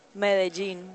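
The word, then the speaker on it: Medellín: M-E-D-E-L-L-I-N. – How do I say it again? – Medellin. – Medellin? Did I do that right? – Yes, good.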